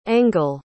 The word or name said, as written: engel